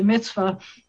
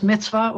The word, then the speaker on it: mitzvah: I'm happy to talk about things because, as you know, we've talked before about there's a word mitzvah, which is sometimes spelled M-I-T-Z-V-A-H, a mitzvah, which can be pronounced mitzvah or mitzvah.